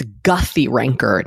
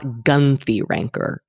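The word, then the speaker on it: Guthy-Renker: It's Guthy Ranker, not Gunthy Ranker.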